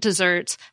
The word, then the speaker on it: desserts: The sweet treat desserts has two S's and the second syllable is stressed.